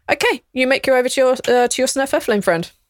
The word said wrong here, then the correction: Svirfneblin: Every time you say Svirfneblin, you butcher it slightly more every time. – Wait, what is it actually? – Svirfneblin. – Svirfneblin. – All right, yes.